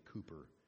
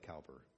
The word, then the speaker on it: Cowper: Sorry, I mispronounced that my whole life. It's actually Cooper, but it looks like Cowper, but it's Cooper.